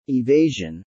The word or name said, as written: evasion